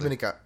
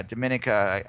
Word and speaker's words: Dominica: Also, I think that, you know, I have this vision because they're playing – it's Dominica, I believe. Is that how you say it? I think it's actually Dominica. Yeah. – Yeah, Dominica. I thought it might have had a different pronunciation on it.